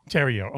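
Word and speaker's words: Theriot: But also your lead guitar player, Shane Terro. Is that how you spell his? You pronounce his last name? – Shane Terrio. Okay. Fantastic guitar player, right?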